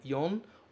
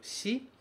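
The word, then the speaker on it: yon (四): O que eu quero que você saiba é: este caractere significa 4 e pode ser lido como yon ou pode ser lido como shi.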